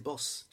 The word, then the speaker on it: Den Bosch: It's spelled Den Bosch, but they don't say Bosch, they say Boss, just to piss off people, unlike every other word in Dutch.